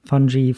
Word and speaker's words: Fungi: Fungi, Fungi. Ist auf jeden Fall die englische Sprachweise, das ist wichtig, weil sonst verwechseln die Leute. – Ich habe gerade im Pre-Talk All About Fungi gesagt.